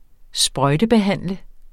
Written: sprøjtebehandle

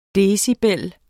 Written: decibel

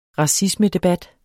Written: racismedebat

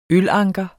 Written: ølanker

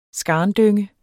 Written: skarndynge

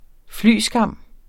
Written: flyskam

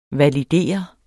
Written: validere

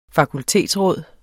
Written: fakultetsråd

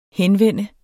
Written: henvende

